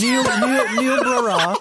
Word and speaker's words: Niobrara: So around 2006 in August, there was a TV hijacking in the county of, I'm going to butcher this, near Niobrara. – Yes. – Niobrara.